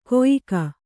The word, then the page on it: ಕೊಯಿಕ: koyika